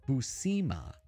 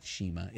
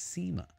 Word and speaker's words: Buscema: Buscema. Yeah, that's right. It's not Shima. It's Seema.